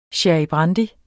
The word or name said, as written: brandy